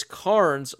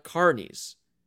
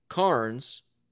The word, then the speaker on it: Carnes: And in her comment, Josh, she puts, I love the podcast, but to help with pronunciation, it's Carnes like Barnes, not Carnies.